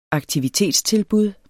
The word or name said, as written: aktivitetstilbud